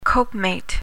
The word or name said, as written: copemate